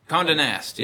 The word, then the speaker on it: Condé Nast: How do you pronounce it? Condé Nast? – Condé Nast, yes.